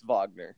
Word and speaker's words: Wagner: Is it Wagner or Wagner? – It's Wagner. – It's Wagner, which that's a point for him right away. I love that. Make it a silent W, go Wagner.